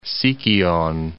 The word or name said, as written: Sikyon